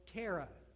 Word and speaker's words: Terah: And it stands for Terah. Rhymes with Sarah. Begins with a T.